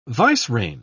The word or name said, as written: vicereine